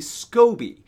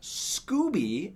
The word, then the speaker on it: SCOBY: and then you add what's called a SCOBY. That's S-C-O-B-Y. SCOBY, not Scooby, as I've heard some people pronounce it.